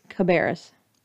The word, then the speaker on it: Cabarrus: And it is, in fact, pronounced Cabarrus.